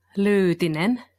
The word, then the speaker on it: Lyytinen: Well, the Finns would say Erja Lyytinen, but to help you guys out, you can say Erja Lyytinen.